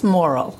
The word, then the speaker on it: Morrill: Yes, Morrill.